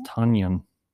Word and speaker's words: Tonyan: Because I'd heard what they had said was it's spelled T-O-N, and so I just think Tanyan. Mm-hmm. But they said, how do you pronounce ton, which is spelled T-O-N? – Mm-hmm. – It's a ton. – No, there you go. So it's Tanyan.